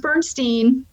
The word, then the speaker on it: Bernstein: His is pronounced Bernstein. They're not related.